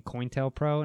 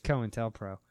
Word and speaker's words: COINTELPRO: I remember one time we were recording and we were doing a show on COINTELPRO and she kept saying COINTELPRO and I'm like, it's COINTELPRO. And she's like, that's just how I like to say it. I'm like, but you're saying it wrong.